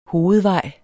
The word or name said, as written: hovedvej